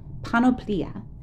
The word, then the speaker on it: panoplia: And so I first went to the Greek word that is used in this Ephesians passage, and it is the word panoplia, and that is spelt P-A-N-O-P-L-I-A, panoplia.